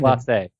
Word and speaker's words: Clase: Class A. Sorry, Emmanuel Class, Class A. A. Class A.